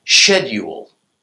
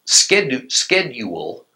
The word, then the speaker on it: schedule: The word schedule is pronounced schedule in England, while pronounced schedule in the USA.